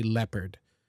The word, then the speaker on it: leopard: We don't say leopard, for example. We say leopard.